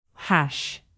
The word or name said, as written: hash